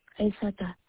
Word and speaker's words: Aissata: First name is Isata, last name is Ba. – Can you spell that, please? – Yes, I see. A-I-S-S-A-T-A, Isata. Ba is B-A-H.